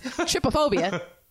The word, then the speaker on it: trypophobia: So when you Google trypophobia, and that's how you pronounce it, trypophobia.